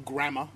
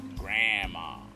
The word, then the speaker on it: GRAAMA: I get, trying to get all the letters in there, but it comes out sounding like Grandma. And it's spelled G-R-A-A-M-A. Grandma.